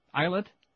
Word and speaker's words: islet: We've done 38, how do you say that? Islet? I-S-L-E-T? I guess that is. Yes.